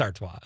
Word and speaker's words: Artois: It's Artois.